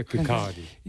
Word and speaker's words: Kirkcaldy: Se pronuncia Kirkcaldy.